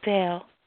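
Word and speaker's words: Del: Because I Dale. – D A Dale. How do you spell? – Well, it's short for a long name. It's D E L. Dale.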